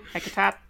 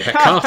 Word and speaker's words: Hecata: Well, I'm anticipating Clan Hecate! I probably said that wrong. Hecate? Hecatat? – Yep. It's the Hecata.